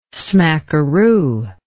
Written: smackeroo

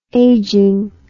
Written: aging

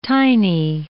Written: tiny

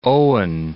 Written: Owen Falls

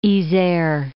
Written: Yser

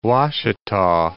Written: Ouachita

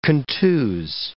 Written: contuse

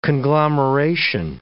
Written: conglomeration

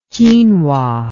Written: quinua

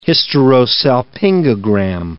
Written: hysterosalpingogram

